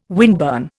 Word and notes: windburn: Indian

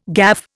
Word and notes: gaffe: Indian

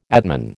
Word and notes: atman: American English

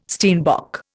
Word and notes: steenbok: Indian

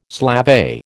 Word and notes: slavey: American English

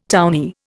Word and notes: townee: Indian

